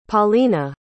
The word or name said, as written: Paulina